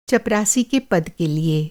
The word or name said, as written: चपरासी